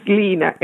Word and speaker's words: Lina: Yes, it's Lina, L-I-N-A.